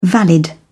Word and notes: valid: British English

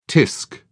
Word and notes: tsk: British English

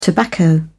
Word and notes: tobacco: British English

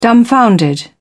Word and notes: dumbfounded: British English

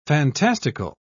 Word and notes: fantastical: British English

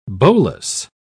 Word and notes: bolas: British English

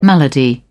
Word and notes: malady: British English

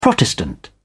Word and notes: protestant: British English

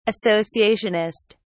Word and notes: associationist: British English

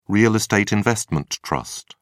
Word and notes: real estate investment trust: British English

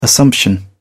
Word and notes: assumption: British English

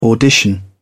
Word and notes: audition: British English

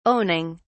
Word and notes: owning: British English

